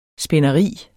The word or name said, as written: spinderi